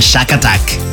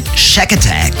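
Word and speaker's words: Shakatak: Und next auf eurer Playlist der 700 beliebtesten Happy Songs der 80er und 90er, Platz 176 und wird oft falsch ausgesprochen, wenn ihr es einfach nur wo stehen seht, sagen viele, das ist ein Schakatak. Ist ein bisschen türkisch-orientalisch angehaucht, sind aber Briten und heißen deswegen Shackatack.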